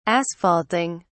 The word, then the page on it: asphalting: British English